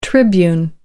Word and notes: tribune: American English

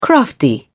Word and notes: crafty: British English

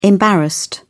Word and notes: embarrassed: British English

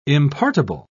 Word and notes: impartible: British English

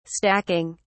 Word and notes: stacking: British English

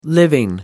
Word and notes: living: American English

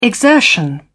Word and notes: exertion: British English

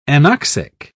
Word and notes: anoxic: British English

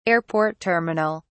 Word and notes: airport terminal: British English

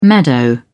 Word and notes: meadow: British English

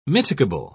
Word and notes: mitigable: British English

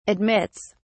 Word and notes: admits: British English

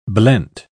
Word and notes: blent: British English

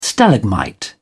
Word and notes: stalagmite: British English

